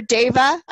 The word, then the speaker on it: deva: And I want to hear all about, now I don't even know this story, the tree diva? – Oh, yes. – Or deva, I guess. Yeah. I mispronounced it.